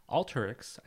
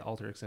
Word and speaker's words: Alteryx: Alteryx, I think that's how you say it, Alteryx,